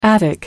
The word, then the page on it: attic: American English